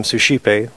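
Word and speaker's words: Suscipe: And the work is, it's branded under this name, Sushipe, which people will read it and they'll not have any clue how to say it.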